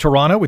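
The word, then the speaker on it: TRNTO: There is an article in, I think it's just pronounced Toronto, it's T-R-N-T-O, and they interviewed real estate expert Benjamin Tall.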